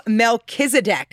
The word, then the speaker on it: Melchizedek: Okay, so Melchizedek. – Yes. – Did I say that right? – That sounds good to me.